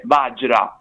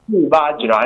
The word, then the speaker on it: Vajra: Basta pronunciarlo come Vajra, è proprio una G, come una G. Vajra è molto facile da pronunciare, sembra difficile vedendolo, in realtà non lo è. Allora, Vajra in realtà è un termine sanscrito e significa diamante o fulmine.